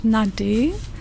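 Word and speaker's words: Nadé: Mein Name wird ganz unterschiedlich ausgesprochen. Ich selbst nenne mich Nade. Das wird geschrieben mit einem Accent aigu. Aber meine Mama nennt mich Nada und Nade, wie auch immer. Also gibt es ganz viele Versionen.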